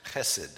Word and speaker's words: chesed: The Hebrew word here is chesed. It was the only thing I was really good at Hebrew, was getting that sound at the beginning of those words where it was called for.